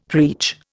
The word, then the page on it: preach: Indian